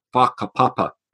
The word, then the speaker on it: whakapapa: You know, there's another idea, not seventh generation thinking, but in Maori culture, there's this concept called whakapapa, spelled with a WH, which they pronounce with an F.